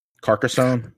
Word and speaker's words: Carcassonne: Carcassonne. – Carcassonne.